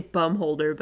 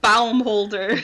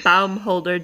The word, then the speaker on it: Baumholder: And after training, Dahmer was stationed in, I want to say Bumholder, but I don't think that's how you say it. – Baumholder. – Baumholder, Germany.